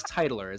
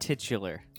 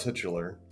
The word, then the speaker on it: titular: But so this question, what is the name of the titular or titler? I still don't know how you pronounce that word. I thought it was titler. Is that right? – I've heard titular. – Yeah, I thought titular.